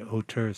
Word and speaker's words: Auteur: So we have an international cast of players here is the Auteur Saxophone. And you spell it A-U-T-E-U-R? – Yes, it's the Auteur.